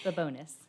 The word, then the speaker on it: Babonis: Very close. Babonis.